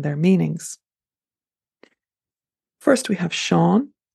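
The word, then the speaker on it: Seán: It is also used as a translation of the English name John, and it's pronounced Sean. Next is Owen, E-O-I-N, which is said to be the actual Gaelic form of the name John, meaning God is gracious.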